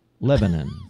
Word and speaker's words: Lebanon: Now, wait, how do we say this? Lebanon. – Yeah, like it's you know, like Cedars of Lebanon. – Okay. – It's three syllables. Lebanon.